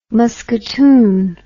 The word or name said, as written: musketoon